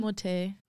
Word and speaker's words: Timothée: Played by Armie Hammer, and Elio is played by Timothy Chalamet. – Shut. – That's not how you say his name. Timothée.